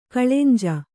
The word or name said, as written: ಕಳೆಂಜಿ